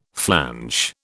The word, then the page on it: flanch: British English